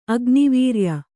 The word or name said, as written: ಅಗ್ನಿವೀರ್ಯ